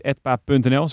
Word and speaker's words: Edpa: Ehm, mocht je nu eh, denken, ik wil eh, handelen op Edpa, website edpa.nl, zeg ik dat goed? Ja, heel goed.